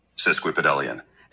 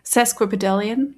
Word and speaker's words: Sesquipedalian: Sesquipedalian. – Sesquipedalian, ich habe es richtig ausgesprochen.